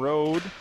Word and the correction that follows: Rody: Excuse me, Aspen Rody. I mispronounced her name.